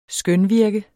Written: skønvirke

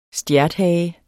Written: stjerthage

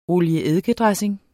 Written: olie-eddike-dressing

